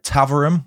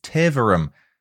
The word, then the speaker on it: Taverham: I also said Taverham when I should have said Taverham.